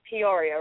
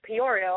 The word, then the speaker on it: Peoria: Now, I am pronouncing it right. It is Peoria, right? – Yes, exactly, exactly. – Thank goodness. I've been saying Peoria all week when I've been talking about saying it right.